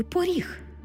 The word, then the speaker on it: поріг: Так само у слові «поріг». Ми-то знаємо, що це слово пишеться з буквою «о». Але якщо будемо писати транскрипцію, ми побачимо, що «о» наближається до «у». Поріг. Почуйте, як я вимовила це слово. Звук «о» наближається до звуку «у», що ми позначимо в транскрипції.